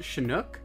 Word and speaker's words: Chinook: It's actually pronounced Chinook.